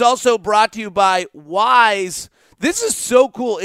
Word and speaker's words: Wyze: Now, it's pronounced Wyze, but it's spelled W-Y-Z-E. That's W-Y-Z-E.